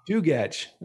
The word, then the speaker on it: Chugach: And he's also worked for the past 9 winters as a full-time heli ski guide in the Kugach Mountains. Is that correct, too? – Chugach.